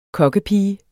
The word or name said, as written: kokkepige